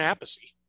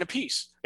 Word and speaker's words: Appice: Are you sure that's how it's pronounced? – I'm sure that's how Carmine pronounces it. – Yes. Go back and listen to that one. – No, I am wrong. It's Carmine Appice. It's Vinny Appice.